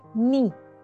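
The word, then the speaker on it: ni (二): El número 2 se pronuncia ni y se asocia al maullido de los gatos.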